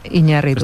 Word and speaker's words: Iñárritu: Iñárritu. – Esto es por lo de antes de Fernando Gómez. – Ya, Para... – Pero muy bien. – Esa firme. – Yo he estado entrenando decir Iñárritu.